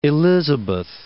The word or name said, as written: Port Elizabeth